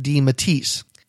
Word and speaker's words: DeMatteis: And after the interview, he and I discussed the pronunciation of the name, and we determined at that time that we were pronouncing it correctly by saying DeMatteis.